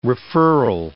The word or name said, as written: referral